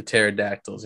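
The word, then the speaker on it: pterodactyl: The PT, the pterodactyls, you know, let's go. Silent P.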